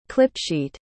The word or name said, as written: clipsheet